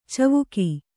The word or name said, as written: ಚಿವಿಕೆ